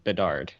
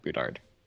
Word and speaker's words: Bedard: It's Bedard, not Boudard. – You got to get that pronunciation right, man.